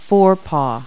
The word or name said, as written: forepaw